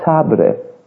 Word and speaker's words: sabre: But what we have heard so far is that we really need a sabre. Do I say it correctly? Almost, yes. How do we say it with a French? Sabre. Yes.